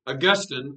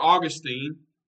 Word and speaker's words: Augustine: One was named Augustine or Augustine, depending on where you're from, you pronounce that differently.